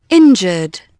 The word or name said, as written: injured